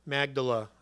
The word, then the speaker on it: Magdala: And he sent away the multitude, got into the boat, and came to the region of Magdala. Sorry. Try to pronounce that 3 times fast. Magdala, I've heard it said.